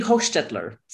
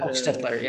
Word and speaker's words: Hochstetler: It would be Hochstetler. So, Hochstetler. – Yeah. It's that long O that confuses people. Yeah. Hochstetler, yeah.